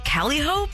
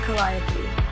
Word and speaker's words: Calliope: And when I saw the bottle and the name, I was wondering, how do you pronounce that? I was like, is it Calihope? But then she posted this commercial and she'll tell you. – Introducing Calliope, the debut fragrance by Megan Maroney.